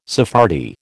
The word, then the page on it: Sephardi: American English